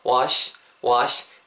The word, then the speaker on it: wash: wash 발음하실 도 wash sh 발음 이렇게 이렇게 입술 둥글게 마실 필요 없고요 wash wash 그냥 깔끔하게 그냥 시 발음하시면 돼요 쉬 발음이 아니고 시예요 시시 시 아시겠죠?